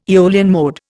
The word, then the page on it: Aeolian mode: Indian